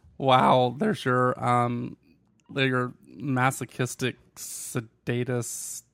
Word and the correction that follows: sadist: You said sedatist. It's sadist.